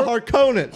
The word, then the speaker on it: Harkonnens: You said it wrong again! – Wait, did I? – Yeah. – Oh, shit. – It's the Harkonnens.